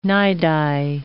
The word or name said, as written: nidi